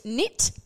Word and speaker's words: knit: Knit. Gnome. – Knit. – So knit and gnome. So now we're going from silent K's to silent G's.